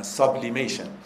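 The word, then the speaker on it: sublimation: تسعید در مقابل کلمه سابلیمیشن انگلیسی به کار رفته، سابلیمیشن S-U-B یورو آ تلفظ من، سابلیمیشن.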